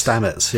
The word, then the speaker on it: Stamets: We meet, Stamets? Is that how you say it? Stamets? – Stamets, yeah.